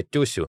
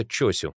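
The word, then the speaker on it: Чосю (長州): Таким образом, княжество Тёсю неизбежно становится Чосю, а эпоха Мурамати — эпохой Мурамачи, то есть приближается к японскому произношению этих слов.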